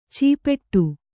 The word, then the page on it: ಚೀಪೆಟ್ಟು: cīpeṭṭu